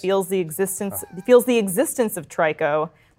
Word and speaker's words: Trico: I believe its name is Trico, or I think that's how it's pronounced, mm-hmm. T-R-I-C-O. – Yeah. Interesting. Yeah. Like Ico, but the third one? – He...